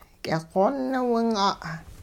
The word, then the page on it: k'iqoonwing'a': hupa